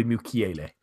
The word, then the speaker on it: Mukiele: Mukiele or Mukele. Nordi Mukiele. I was closer than I thought yeah.